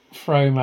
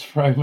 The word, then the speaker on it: Frome: he's put, it's not Frome as in Rome, it's Frome as in room. Very quick pronunciation. Frome.